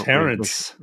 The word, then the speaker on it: Tarrants: The Tarrants. Rhymes with parents.